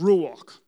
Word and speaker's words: ruach: But the Holy Spirit in, in Hebrew is pronounced ruach, right?